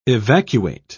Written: evacuate

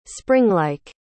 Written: springlike

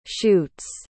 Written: shutes

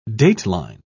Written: dateline

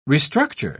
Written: restructure

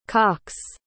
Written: cocks

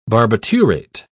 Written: barbiturate

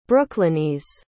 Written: brooklynese

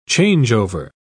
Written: changeover

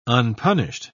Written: unpunished

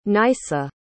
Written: Nysa

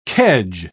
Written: kedge

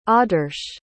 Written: adarsh

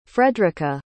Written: fredrica

